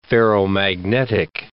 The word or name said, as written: ferromagnetic